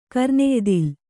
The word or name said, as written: ಕರ್ನೆಯ್ದಿಲ್